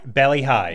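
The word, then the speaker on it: Bali Hai: Bally High.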